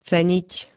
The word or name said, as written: ceniť